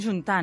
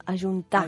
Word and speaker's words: ajuntar: També tenim juntar, no? – Juntar, que s'hauria de pronunciar amb aquesta A inicial, ajuntar.